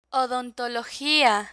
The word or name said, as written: odontologia